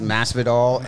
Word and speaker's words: Masvidal: How do you say it? Masvidal? – Yes, Masvidal and Diaz.